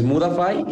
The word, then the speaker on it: Mudafy: Eh, Modify se escribe M-U-D-I. F. – es Mudafy y es M-U-D-A-F-Y.